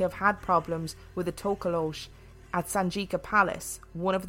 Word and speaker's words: Tokoloshe: Just before we go any further, so I looked this up, and Tokolosh is just the most commonly used pronunciation, but there are lots of other pronunciations of Tokolosh.